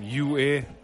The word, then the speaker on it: Ua: How do you spell that? – U-A.